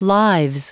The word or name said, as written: lives